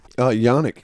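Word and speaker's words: Jannik: Yannick, yes.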